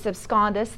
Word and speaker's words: absconditus: I'm sorry, Latin term called deus absconditus, D-E-U-S. A-B-S-C-O-N-D-I-T-U-S, absconditus.